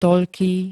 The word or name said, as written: toľký